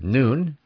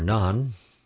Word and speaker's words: Nun: Joshua, the son of Nun, or Yasha, was originally named Oshea, O-S-H-E-A, in Numbers 13. His father's name, Nun, pronounced Nun, or Nun, means perpetuity, perpetuity.